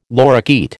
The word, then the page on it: lorikeet: American English